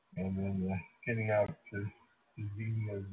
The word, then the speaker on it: Xenia: And yeah, I think it's pronounced Xenia. Kind of like there was a TV show, Xena, Warrior Princess, something like that. So anyway, long E, and it sounds like it starts with a Z, Xenia.